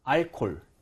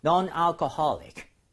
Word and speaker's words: alcoholic: 이게 H가 살아 있습니다. 근데 많은 분들이 H가 자음인데도 불구하고 이거를 그냥 무시하고 알콜, 알콜 이렇게 하시는 분들이 많은데 H를 발음하셔야 돼요. – 네. – So alcoholic 이런 식으로 alcoholic H가 꼭 발음이 돼야 됩니다.